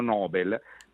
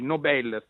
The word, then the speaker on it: Nobel: Allora, anzi, Nobel, sto sbagliando sempre la pronuncia,